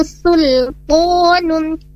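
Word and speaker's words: sultanun (سلطان): Sa si su, innahu laisa lahu sultanun, sul bukan sul, sul tapi sul, dia tipis, huruf sinnya tipis karena bukan merupakan huruf istimewa, tapi huruf istifalnya memiliki sifat istifal, di sini ditipiskan saat membacanya.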